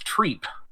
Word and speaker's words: Trepe: Yeah, Quistis is it's the last name they argue about, because T-R-E-P-E in French would be pronounced trep, but according to the lore, it's treep.